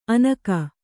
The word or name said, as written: ಅನಕ